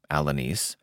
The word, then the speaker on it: Alanis: In 1941, when Alanis, pronounced Alanis,